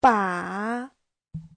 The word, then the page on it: 把: ba3